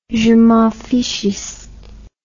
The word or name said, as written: jemenfichiste